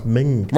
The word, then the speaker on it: Menzies: Is Bing spelled B-I-N-Z-I-E-S? G? No, because, you know, like Ming Campbell. He's called Menzies Campbell, but it's pronounced Ming.